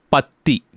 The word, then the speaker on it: பற்றி: The sound for about in Tamil is patti. Patti. P-A-T-T-I.